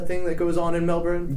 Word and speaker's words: Melbourne: But I took phonetics classes, and the R is there, so I'm going to stick with my American accent and say Melbourne.